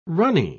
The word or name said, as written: runny